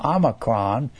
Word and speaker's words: omicron: Then you've got, so you got O-P-R-S-T-U. The O looks like our O. That's an Omicron. It's pronounced ah.